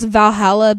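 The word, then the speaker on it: VA-11 Hall-A: And I'm sure it's just pronounced Valhalla, but it's VA11 Hall A.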